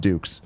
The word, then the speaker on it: Dux: Founded in early 2014 by husband and wife Vicky and Rich Fulop and their dog Ducks. Oh, nope. It's pronounced Dukes. The dog Dukes, but it's spelled D-U-X.